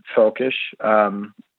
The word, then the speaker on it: völkisch: It's sometimes translated literally into English as Völkisch, F-O-L-K-I-S-H, which is more or less how you pronounce it in German, Völkisch,